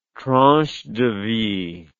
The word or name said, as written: tranche de vie